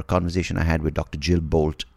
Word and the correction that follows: Bolte: Taylor about Jill Bolte. I think she pronounced herself Jill Bolte Taylor.